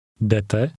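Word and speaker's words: jdete: The first letter will be almost silent. – Dete. Dete.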